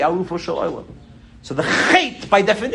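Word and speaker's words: chet (חטא): When it comes to the word chet, ha'alif eino nikar bahavar. When a person says the word chet, the aleph is not pronounced. It's a silent aleph.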